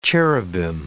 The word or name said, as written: cherubim